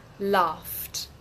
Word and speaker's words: laughed: Loved, laughed, ¿ok? I laughed because I loved you. I laughed because I loved you. Laughed es con T, love es con D.